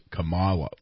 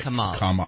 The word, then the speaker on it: Kamala: Yeah, but and as I used to say Kamala, but it's Kamala. Like Kamala.